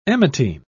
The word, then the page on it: emetine: British English